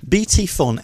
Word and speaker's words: BT Fon: No, you've mispronounced it. Phone. – F-O-N. – No, it's silent P-H. – BT Fon, F-O-N.